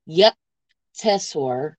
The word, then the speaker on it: Yukteswar: Oh God, you left me one of those big names that are Yukteswar. – Yukteswar, yeah, you said it right.